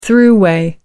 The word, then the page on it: throughway: British English